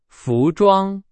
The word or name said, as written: 服装